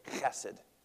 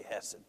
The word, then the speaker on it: Chesed: Really in Hebrew you say Chesed. It's spelled C-H when you transliterate it. Chesed, love. But I'm not going to do that every time. We just say Hesed, okay? We're not real guttural like Hebrew.